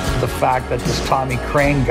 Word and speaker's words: Krein: Crane Shames, C-R-Y-A-N, and then Shames, which is phonetically the same way you would pronounce Tommy Crane's last name, which is spelled K-R-E-I-N.